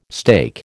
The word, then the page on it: steak: American English